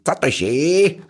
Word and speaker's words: Zatoichi: aber ich sagte jetzt mal, der Film mit den meisten Fortsetzungen heißt Zatoishi oder Zat, also schreibt sich Zatoishi oder spricht sich Zatoishi aus, ist eine, ein sogenannter Jedi-Geki-Film, ein historischer, ein japanischer Historienfilm.